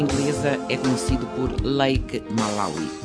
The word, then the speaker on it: Malawi: Malawi, palavra aportuguesada para Malawi, Malawi, Malawi e ainda Malawi, que é a pronúncia mais próxima da língua Sinianja, à qual aquela palavra pertence.